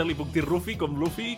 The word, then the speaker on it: Luffy: És Rufi, Rufi, Luffy, en sí, japonès, sí. i sona com una R i va deixar Rufi. Passa que diem massa, pronunciem massa la R nosaltres, com yeah. ratolí, i acabem dient Rufi.